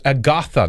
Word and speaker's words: Agatha: Chalky Milk said it's pronounced Agatha. That's right. Agatha Christie. You're right. Thank you for the little correction there.